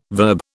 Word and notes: verb: British English

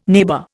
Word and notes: neighbour: Indian

